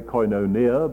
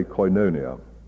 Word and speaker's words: koinonia: Sorry, here I suppose I ought to say koinonia, but I was brought up in a rather old-fashioned way of pronouncing Greek, and I say koinonia.